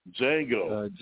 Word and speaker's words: Django: Django. – Django. – Like the D is silent.